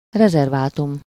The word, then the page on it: reserve: [rɪ.ˈzɜːv]; American English